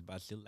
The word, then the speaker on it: Basil: Hi guys, my name is Basil. It's pronounced as Basil, like the herb, but the herb is pronounced as Basil. But I'm a Muslim, so we call it Basil here.